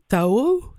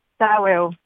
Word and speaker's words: Taweo: Mais là, ça s'appelle, je veux bien le prononcer, mais Tao, c'est comme ça qu'on prononce? – Taweo, ouais.